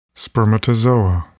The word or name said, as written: spermatozoa